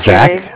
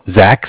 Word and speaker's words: Zach: My name is Zach. Zach, spelled Z-A-C-H. – Okay. – Calling from Bank of Cardiff, and Cardiff is spelled C-A-R, like car, D as in dog, I as in imagination, F-F as in Frank.